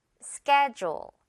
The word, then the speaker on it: schedule: D가 지읒으로 소리 나는 schedule. 이렇게 줄 발음으로 갑니다.